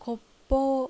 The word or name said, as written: Koppou